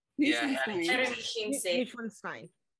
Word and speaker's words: Neuschwanstein: Neuschwanstein.